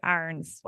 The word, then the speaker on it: Arens: Arens like a pirate. Arens. It looks like Arens, but apparently it's Austrian, so it's a hard R.